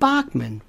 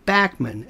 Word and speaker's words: Bachman: Randy Bachman, not Bachman, Bachman, as he pronounces it.